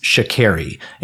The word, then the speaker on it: Sha'Carri: Start learning how to pronounce her name. It's Sha'Carri.